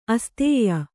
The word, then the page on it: ಅಸ್ಥಾಯಿ: asthāyi